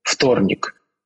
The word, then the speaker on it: вторник: Например, что-нибудь вроде «вт», «вторник». Пишется буква «в», и это звонкая буква, да? Произносится «ф», потому что потом согласный. «Вторник».